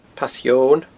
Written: Pass Eoghain

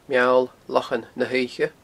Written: Meall Lochan na h-Oidhche

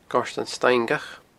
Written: Goirtean Staingeach

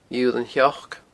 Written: Iodhlann Sheoic

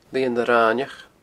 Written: Lianag Raineach

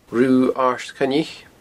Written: Rubha Aird Choinnich

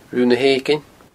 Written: Rubha na h-Eiginne

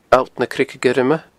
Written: alltnacreigeguirme

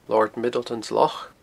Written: Lord Middleton's Loch

